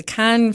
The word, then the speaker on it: Cannes: And I want to just mention that, by the way, the E-S at the end is silent. So it's C-A-N-N-E-S. But I've learned from Valerie, who's the French teacher, we say Cannes.